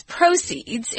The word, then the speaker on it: proceeds: So he takes the proceeds, and it's not proceeds, it's proceeds. It's pronounced differently. So the accent is on the first syllable, proceeds, And he donates it to a good charity.